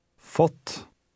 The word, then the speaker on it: fått: Fått. Fått. – Notice how this vowel sounds when it is followed by a double consonant.